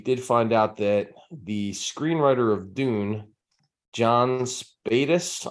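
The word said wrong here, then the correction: Spaihts: I guess is how you say his name. – Spates.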